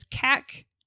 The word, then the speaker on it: cachexia: So the phonetic pronunciation is cachexia. So cachexia. – Cachexia. – It comes from the Greek word kakos.